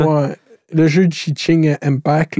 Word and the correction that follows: Genshin Impact: là, je sais pas comment le prononcer. – Genshin Impact. Vas-y.